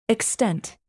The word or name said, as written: extent